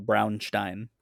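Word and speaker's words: Braunstein: Now, Bronstein was an experiment... – Brown. You don't have to roll your R, but it's brown, like the color. Brownstein. There you go.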